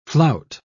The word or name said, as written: flout